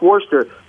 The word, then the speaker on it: Worcestershire: Non, it's written Worcestershire, but it's Worcester.